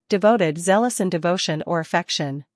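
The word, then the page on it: devoted: uk dɪˈvəʊtɪd; en-us dɪˈvoʊtɪd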